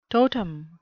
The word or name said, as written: totem